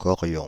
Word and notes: chorions: France (Île-de-France); /kɔ.ʁjɔ̃/; /ʃɔ.ʁjɔ̃/

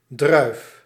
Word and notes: raisin: France; [lə.ʀɛ.zɛ̃]; French

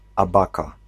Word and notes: abaca: /a.ba.ka/

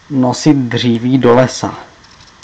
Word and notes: nosit dříví do lesa: /ˈnɔsɪt ˈdr̝iːviː ˌdɔˈlɛsa/

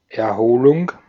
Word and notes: Erholung: /ɛɐ̯'hoːlʊŋ/

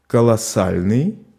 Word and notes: colossal: France; [kɔ.lɔ.sal]

